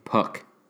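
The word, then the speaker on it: Puk: With that, how do you say his name? Puke? – AJ Puck. Puke Puck.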